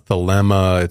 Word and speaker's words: Thelema: So thelema, thelema, it's been pronounced a few different ways. I think going forward, we'll just pronounce it thelema.